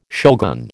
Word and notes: shogun: American English

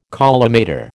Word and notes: collimator: American English

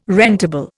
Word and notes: rentable: Indian

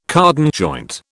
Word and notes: cardan joint: British English

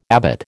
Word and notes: abbot: American English

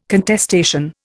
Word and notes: contestation: Indian

